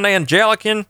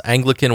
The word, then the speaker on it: Anglican: I was like, Is that an Angelican? Which is what most people uh, in this country pronounce Anglican.